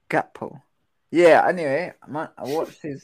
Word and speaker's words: Gakpo: Gakpo. – Yeah, what's come on, you can't just be skipping out a random, like, it's a K. There's no such thing as a silent K.